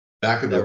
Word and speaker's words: Vacaville: I'm saying that right? Vacaville? – Vacaville, right? Correct.